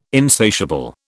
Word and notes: insatiable: British English

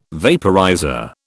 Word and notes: vaporizer: British English

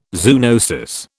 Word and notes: zoonosis: British English

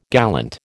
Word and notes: gallant: American English

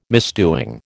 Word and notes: misdoing: American English

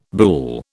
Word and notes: buhl: British English

